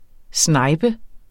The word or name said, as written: snipe